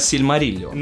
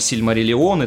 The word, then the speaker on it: Сильмариллион: «Сильмариллион», если вы прочитаете, кстати, правильное ударение — я буду следить сегодня за правильными ударениями. – Ой-ой-ой. Именно Сильмариллион. На этом мы очень сильно, я думаю, погорим. А вовсе не Сильмариллион и так далее.